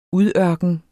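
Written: UDØRKENERNES

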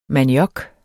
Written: maniok

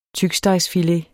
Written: tykstegsfilet

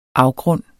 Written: afgrund

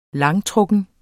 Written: langtrukken

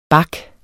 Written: back

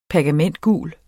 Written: pergamentgul

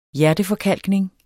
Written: hjerteforkalkning

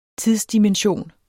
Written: tidsdimension

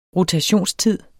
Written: rotationstid

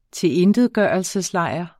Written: tilintetgørelseslejr

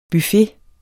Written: BUFFETERNES

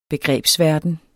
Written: begrebsverden

